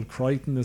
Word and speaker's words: Crichton: But I'm talking about, like, if you want to talk about is it Michael Crichton, is that how you pronounce his name? – Yes.